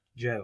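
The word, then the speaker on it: Gaol: Derby Gaul, isn't it? Yeah, Derby Gaul. – Yeah. – Is that what? – It's pronounced jail. – But it's not spelled like that, Gaul.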